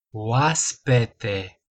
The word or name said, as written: oaspete